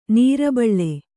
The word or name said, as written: ನೀರ್ಬೀಳು